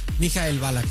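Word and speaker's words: Ballack: ¿Cómo se dice bien? ¿Mikhail Balak? – Mikhail Balak, sí.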